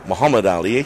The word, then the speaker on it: Muhammad Ali: Muhammad Ali, to give you the correct pronunciation.